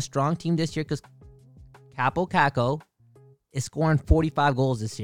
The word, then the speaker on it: Kaapo Kakko: Kaapo Kakko. – Kaapo Kakko.